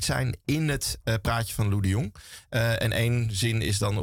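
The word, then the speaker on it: Loe de Jong: Lou de Jong, dat was de hoofdomroeper van Radio Oranje.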